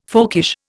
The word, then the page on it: folkish: Indian